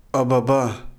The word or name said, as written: ababa